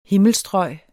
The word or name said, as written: himmelstrøg